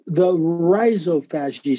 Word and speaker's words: rhizophagy: The rhizophagy cycle. Now, that's how I pronounce the word, R-H-I-Z-O-P-H-A-G-Y, rhizo meaning root, phagy meaning eating, root eating.